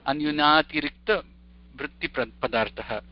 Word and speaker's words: अन्यूनातिरिक्तवृत्तिपदार्थः: उच्चारणम् एवं महोदय अन्यूनातिरिक्तवृत्तिपदार्थः अन्यूनातिरिक्तवृत्तिपदार्थः